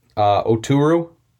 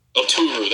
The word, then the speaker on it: Oturu: Oturu? – Oturu, that's right.